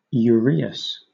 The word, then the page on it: uraeus: British English